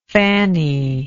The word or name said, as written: Fannie